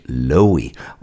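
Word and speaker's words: Loewe: Ja, han som skrev den, ja, det är nog många som undrar om man uttalar det efternamnet Lowy, ska man säga. Frederick Lowy.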